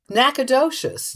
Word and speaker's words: Nacogdoches: N-A-C-O-D-O-C-H-E-S. You think it's like Nacogdoches or something. How do you say this? – Nacogdoches. – Nacogdoches. – You were pretty much there.